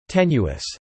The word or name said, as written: tenuous